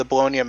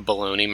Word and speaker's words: Bologna: Bologna Massacre, which happened on August 2nd, 1980. – I'm going to need you to pronounce that correctly. It's the Bologna Massacre.